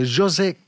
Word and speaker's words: José: Perdutamente innamorato naturalmente è pronto adesso però per presentarci il prossimo artista, ovvero eh, José Gonzales. Eh, eh, eh, José. José, José, lo José. pronunciamo proprio così.